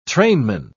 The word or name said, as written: trainman